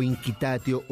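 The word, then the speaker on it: Incitatus: narran que incitatio o inquitatio o incitatio, depende de la pronunciación que se pueda tener eh, según eh, la, época y el lugar en el cual se esté pronunciando esta, esta lengua en latín, eh, incitatio, incitatio, era el caballo consentido de Calígula.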